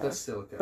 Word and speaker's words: Basilica: Am I saying that right? I'm not. Basilica. – Basilica. – Basilica.